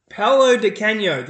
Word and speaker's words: Paolo Di Canio: Paolo Di Canio.